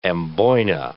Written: amboyna

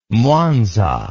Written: mwanza

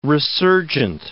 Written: resurgent

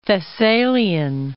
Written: Thessalian

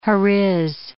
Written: Heriz

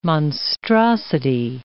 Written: monstrosity

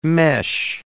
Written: mesh

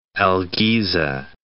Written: El Giza

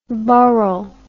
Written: burhel

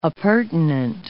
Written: appurtenant

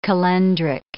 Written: calendric

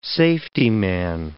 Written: safetyman

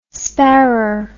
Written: sparrer

